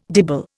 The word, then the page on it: dibble: Indian